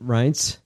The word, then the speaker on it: Reince: Reince. – Reince! Why are you being such a pussy at this point? You signed up. Are you surprised? Are we supposed to feel like Reince Priebus is surprised?